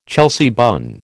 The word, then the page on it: Chelsea bun: American English